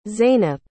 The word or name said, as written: zeynep